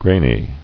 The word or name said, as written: Grainy